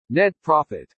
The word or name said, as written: NET PROFIT